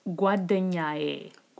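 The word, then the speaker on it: Guadanyaye (ጓደኛዬ): am I saying it right? Guadanye? – Almost. So it's Guadanyaye.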